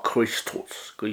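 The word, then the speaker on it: 크리스토스 (Χριστός): 그래서 영어로 이제 뭐 그죠 카 크리스토스 그게 헬라의 오리지널 그 발음입니다.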